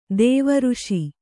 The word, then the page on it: ಋಷಿ: řṣi